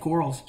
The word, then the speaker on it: Quarles: It's just my name, Jeff Quarles.